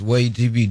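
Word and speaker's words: WAYH TV: Way TV, am I saying it right? – Yes, Way TV, WAYH.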